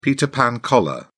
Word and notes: peter pan collar: British English

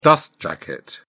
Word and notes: dust jacket: British English

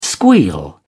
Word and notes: squeal: British English

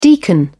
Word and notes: deacon: British English

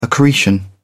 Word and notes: accretion: British English

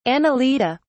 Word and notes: annelida: British English

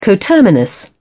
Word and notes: coterminous: British English

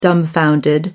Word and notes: dumbfounded: American English

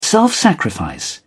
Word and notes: self sacrifice: British English

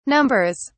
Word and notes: numbers: British English

